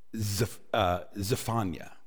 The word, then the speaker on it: Zephaniah: His name in Hebrew is pronounced Zephaniah.